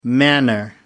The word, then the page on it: manor: American English